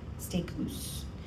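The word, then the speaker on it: Stachys: Our co-worker Christ and my dear friend Stakus. Y's are pronounced like U's in Greek. – Stakus.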